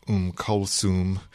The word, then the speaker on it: Kulthum: We're talking about Kulthum, sometimes known as Kulsum, and probably two or three other pronunciations.